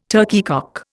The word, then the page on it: turkeycock: Indian